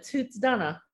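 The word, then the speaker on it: Tuatha Dé Danann: So what's your knowledge of the Tathath Dé Danann? – We call it Tuath Danann. – Okay, Danann, yes.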